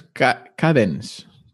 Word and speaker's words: Kadence: Ah, no, es Cadence. Cadence. Cadence. K-A-D-E-N-C-E.